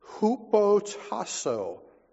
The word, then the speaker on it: hupotasso: The primary Greek word for submission is hupotasso, and I will spell it should you want to look it up someday. H-U-P-O. T-A-S-S-O, hutoso.